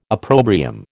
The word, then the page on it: opprobrium: American English